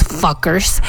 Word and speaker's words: FCUKERS: el nombre del proyecto es Fuckers, no se escribe así, se escribe con FC, FCUKERS, es una banda, bueno, es un proyecto, es un dúo más bien de música electrónica.